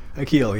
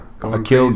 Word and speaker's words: Akil: Akil, yes, that's right. – So, should I be saying Akil Goin? – Goin. Akil Goin.